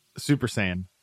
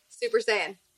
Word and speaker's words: Saiyan: Am I saying that right? Saiyan. – Super Saiyan. – Super Saiyan. There you go. – You were almost like seance there, which is a different genre.